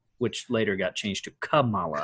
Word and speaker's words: Kamala: So I think the easiest way to remember it is definitely, I think we've talked about it before, is like the like a comma, like in English class, you know, when you put a comma into something. So if you say Kamala, that's, you're saying it right.